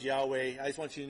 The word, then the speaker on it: Yahweh: So I grew up with hearing Jehovah. And somewhere along the line, the scholars are like, no, that doesn't really seem right. And so they started pronouncing it Yahweh. It's the same vowels, the same consonants with just different vowel soundings.